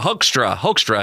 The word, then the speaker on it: Hoekstra: Pete Hextra, Hoekstra, I think is how you pronounce Pete Hoekstra.